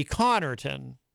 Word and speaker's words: Connarton: But which is strange because the first syllable ends in two consonants, C-O-N-N. So usually if you have a name or any word where you've got a syllable that ends in two consonants of the same letter, a double N, you should know that that's where the emphasis goes. So that it would be Connarton. You would put the emphasis on the C-O-N-N because it's a double N.